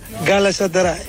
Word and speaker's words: Galatasaray: Eu disse Galatasaray. Olha, o Bruma é que diz Galatasaray na perfeição. Vamos ouvir. – Campeão Galatasaray. – Olha! Trocou aqui 2 sílabazinhas, mas fica bem.